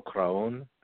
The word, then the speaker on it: Cuarón: And it's pronounced Alfonso Cuarón.